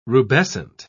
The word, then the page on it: rubescent: British English